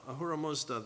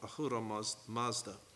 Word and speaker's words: Ahura Mazda: Ahura Mazda, they call him. I pronounced that wrong. Ahura Mazda.